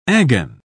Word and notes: agon: British English

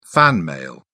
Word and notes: fan-mail: British English

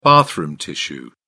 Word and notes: bathroom tissue: British English

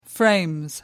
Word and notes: frames: British English

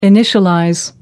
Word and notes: initialize: American English

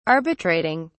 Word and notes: arbitrating: British English

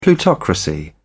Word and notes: plutocracy: British English

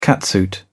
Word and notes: catsuit: British English

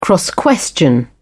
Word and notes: cross-question: British English

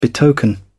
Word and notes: betoken: British English

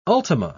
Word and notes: ultima: British English

